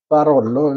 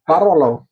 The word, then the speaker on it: Barolo: Precis, så det här är ju från då Barolo, nu vet jag inte exakt vad det ligger. Vi har exakt hur det uttalas. – Barolo. – Barolo.